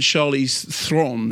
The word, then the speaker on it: Charlize Theron: I did an on-stage interview with him and Taylor Hackford and Charlize Theron, as it turns out it's pronounced.